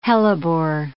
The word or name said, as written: hellebore